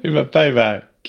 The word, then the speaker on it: hyvä päivä: Huva päivä. – Hyvä päivä, Kimberly.